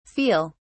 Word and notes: feal: British English

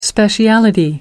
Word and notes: speciality: American English

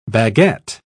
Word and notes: baguet: British English

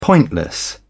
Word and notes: pointless: British English

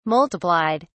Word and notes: multiplied: British English

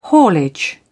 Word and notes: haulage: British English

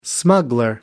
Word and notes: smuggler: American English

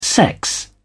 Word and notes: sex: British English

